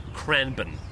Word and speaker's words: Cranbourne: But I've actually been mispronouncing it my whole life. It turns out it's Cranbourne.